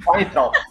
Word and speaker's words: Weintraub: E aí vai o, o Weintraub, cara. – Não, por favor, pronuncie corretamente o nome. Weintraub. Isso.